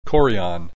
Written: chorion

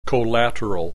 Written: collateral

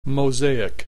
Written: mosaic

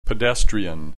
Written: pedestrian

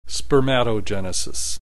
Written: spermatogenesis

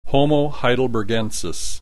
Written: Homo heidelbergensis

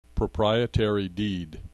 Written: proprietary deed

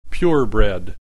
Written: purebred

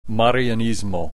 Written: marianismo

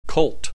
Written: cult

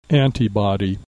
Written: antibody

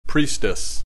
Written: priestess

